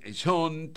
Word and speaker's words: Sean: Para aclararlo, John Lennon se escribe con J-O-H-N, ¿verdad? Y este sería John también, pero como si fuera Sean Connery. Sean Lennon, digo, para identificarlos, se pronuncia John también Lennon, nació en 1975, que es hijo de John Lennon con Yoko Ono, ¿no?